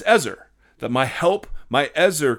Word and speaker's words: Ezer: It is an awful translation of the word Ezer or Ezer. I've heard it pronounced both ways. I'm going to pronounce it Ezer.